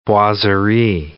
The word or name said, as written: boiserie